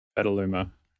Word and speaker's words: Petaluma: What made you move to Petaluma? – Petaluma. – Is that how you say it? – Yeah, I lived in Santa Cruz for a while.